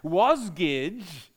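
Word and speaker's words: Wasgij: Wasgidge is the word jigsaw pronounced backwards.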